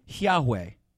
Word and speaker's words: Yahweh: So we would pronounce God's name Yahweh.